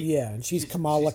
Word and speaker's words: Kamala: Yeah, now Miss Marvel is Kamala Khan. And yes, the comic book is pronounced Kamala. The vice presidential candidate is Kamala. So we're learning to pronounce the same name different ways.